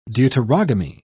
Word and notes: deuterogamy: British English

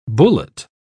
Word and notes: bullate: British English

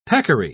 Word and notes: peccary: British English

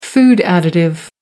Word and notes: food additive: American English

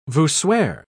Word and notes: voussoir: British English

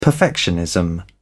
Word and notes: perfectionism: British English

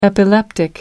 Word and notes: epileptic: American English

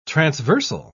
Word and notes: transversal: British English

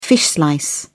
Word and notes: fish slice: British English

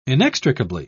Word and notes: inextricably: British English

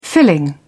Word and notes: filling: British English

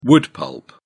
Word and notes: wood pulp: British English

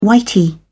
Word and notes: whitey: British English

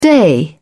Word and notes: day: British English

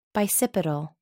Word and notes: bicipital: American English

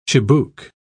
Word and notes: chibouque: British English

